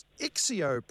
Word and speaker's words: Excio: Now, have I pronounced Ixio properly? E-X-C-I-O. – Yes, that's exactly right.